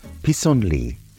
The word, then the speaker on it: pissenlit: Dandelions in French are pronounced pissenlit and spelt P-I-S-S-E-N-L-I-T-S.